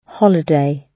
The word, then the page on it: holiday: British English